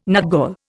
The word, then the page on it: nutgall: Indian